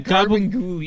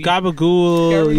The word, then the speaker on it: Gurbanguly: I feel like his name is definitely Gurbanguly. – Gurbanguly.